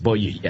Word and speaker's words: Boiuna: No, so yes, it's B-O-I-U-N-A. – Yeah. – B-O-I-U-N-A. There it is. Boyuna.